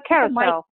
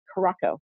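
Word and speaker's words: Curaçao: Well, a lot of people say it Caraco. It is actually pronounced carousel. In Papamiento.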